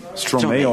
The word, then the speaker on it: Stromae: Yes, Stromae, or as you want. It depends of the country where I go. Even in France, they pronounce it differently than in Belgium. – Yeah. – Actually, I say it like Stromae, and in France, they say Stromae.